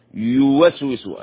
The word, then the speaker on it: يوسوس: يوسوس أي الياء هنا مضمومة يوسوس يوسوس يوسوس هكذا تنطق يوسوس